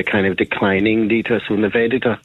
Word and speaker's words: Nivedita: I've heard it often pronounced the way you pronounced it. I asked Lord Rana to pronounce it for me, and he pronounced it more like Nivedita. You know, and then I asked a Hindu nun in Calcutta to pronounce it for me, and it was Nivedita.